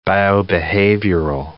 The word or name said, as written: biobehavioral